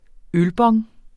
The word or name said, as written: ølbong